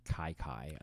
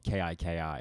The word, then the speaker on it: Kiki: Like there was one for like Kai Kai that shows up later in the season, and it's spelled K I K I.